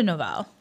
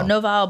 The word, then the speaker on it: Noval: and we're going to start off with the is it Noval or Noval? I don't know how to pronounce this correctly. – Quinto Noval. So Noval Black.